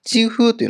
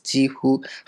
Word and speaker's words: 几乎: 几乎というのはほとんどという意味です。几乎。発音に注意ですね。あの、几年だ几ですけど、几乎の場合は第一声になります。几乎ですね。二つとも一声です。